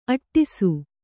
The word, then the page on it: ಅಟ್ಟಿಸು: aṭṭisu